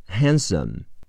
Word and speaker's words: handsome: Handsome，和 a h 是 h a，前鼻音 n 是字母 n，han 的不发音。 s a s 是 s o，n 是 m，结尾的 e 不发音。